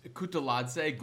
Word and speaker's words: Kutuladze: It's Kutuladze, Guram Kutuladze, by the way. That's just so you know how to say the names of the fighters that we're talking about. But go ahead. – There's multiple T's separated by a vowel. Kutataladze. You're going to correct me. Be on point, motherfucker.